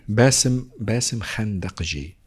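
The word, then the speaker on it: Bassem Khandakji: El escritor Bassi Kandahi. ¿Cómo se dice? ¿Tú lo conoces? – Repite el nombre, por favor. – Bassi Kandakji. – Bassem Kandakji.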